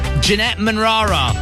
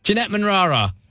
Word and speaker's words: Jeanette Manrara: Jeanette Manrara. Did I say that right? Jeanette Manrara. – Yeah, good.